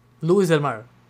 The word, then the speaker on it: Lewis del Mar: Luis del Mar, que se escribe Lewis del Mar.